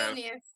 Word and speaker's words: Vilnius: And so I following you on social media, I've seen that you've been fortunate to go to, say, CrossFit Vilnius, right? If I'm saying that right. Vilnius? – So, Vilnius.